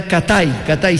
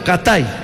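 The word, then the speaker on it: CatEye: Me acuerdo de una primera marca, era Katai, se escribe Katalle, ¿no? O sea, Kat era ojo de gato, ¿no? Kat y Ay, ¿no? Ojo de gato, Katai, ¿no?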